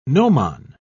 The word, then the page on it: gnomon: British English